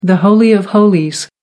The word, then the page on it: holy of holies: American English